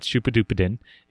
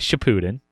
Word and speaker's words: Shippuden: It's not Shupadupadin. It is Shippuden.